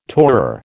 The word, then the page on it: tourer: American English